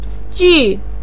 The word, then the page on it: ju: 4